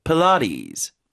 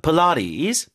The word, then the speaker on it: Pilates: 필라테스도 라의 강세거든요. 그러니까 강세가 없는 음절은 으로 약화시키는 중성 모음화, 즉 슈아 현상. 그러면 P가 아니라 푸예요. Pilates. – Pilates. – Pilates. – Pilates. – 푸에 가깝죠? Pilates.